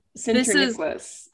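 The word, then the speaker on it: Sinterniklaas: It's Sinterniklas.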